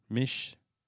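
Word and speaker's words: Misch: Mish.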